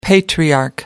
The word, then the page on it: patriarch: American English